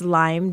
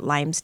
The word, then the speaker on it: Lyme: But here in the Midwest, we have found, after being here the last couple of years, that a lot of people pronounce it Lyme's disease, and it's just Lyme, just no S. We only need one. We don't need two.